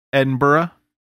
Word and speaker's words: Edinburgh: But it was filmed and set in Edinburgh, which I don't really know like the right way to say that. But that is also. – You said it right. – Did I do it right?